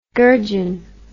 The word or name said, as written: gurjun